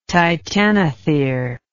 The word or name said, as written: titanothere